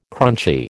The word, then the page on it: crunchy: American English